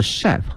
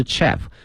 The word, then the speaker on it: chef: 同时啊，这个词呢它还是一个法语词，它的发音呢是 chef 啊，是一个法语词，不是 chef。